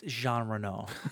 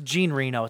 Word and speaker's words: Jean Reno: At this point in your life, do you say that name wrong just because? – It's Jean Reno. I know how you say his name. It's Gene Reno. It's how I've always said his name. – But you sound stupid when you say it like that.